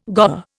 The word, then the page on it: goer: Indian